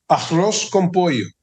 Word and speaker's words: arroz con pollo: Arroz con pollo.